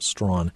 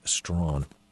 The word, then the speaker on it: Strachan: By the way, if you go and look for the book, his last name is spelled S-T-R-A-C-H-A-N, pronounced Strawn, Hugh Strawn.